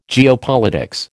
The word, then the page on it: geopolitics: American English